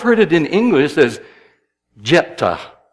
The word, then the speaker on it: Jephthah: I think I'm going to choose to put the accent where the Hebrew puts it, and I'm going to call him Jephthah.